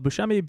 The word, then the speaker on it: Buscemi: My last name is spelled Buscemi, B-U-S-C-E-M-I, and all my good stuff will come up there.